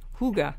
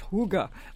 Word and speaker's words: hygge: And don't forget, people, it's spelled, The Higgy Life is the name of the book, and it's spelled H-Y-G-G-E, but you pronounce it in the language Huga. You know, H-O-O-G-U-H, Huga, like that.